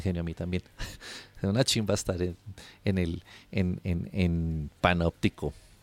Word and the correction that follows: Panopticon: Panopticon, perdón, Juan, que lo dije mal.